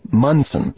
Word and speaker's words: Monson: First things first, it's spelled M-O-N-S-O-N, but it's pronounced Munson, as in Dan Munson, the new head coach of the Minnesota Gopher basketball team, the former head coach of Gonzaga.